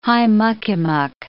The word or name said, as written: high-muck-a-muck